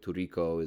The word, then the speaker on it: tricot: Spelled tricot, T-R-I-C-O-T. I don't know if I'm pronouncing it exactly right, but Turiko is close to what it is. It sounds Spanish when I'm doing it. It's a Japanese. It's a Japanese word.